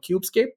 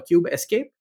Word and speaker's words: Kubescape: There is an open source tool called KubeScape or KubeScape, based on how you choose to pronounce it.